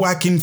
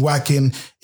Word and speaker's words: Joaquin: based upon the trailer, and you know, and Whacking Phoenix. That's his name. Whacking. It's um spelled J O A Q U I N you know, but it's pronounced Joaquin Phoenix.